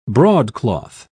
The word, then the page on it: broadcloth: British English